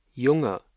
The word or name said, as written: Junge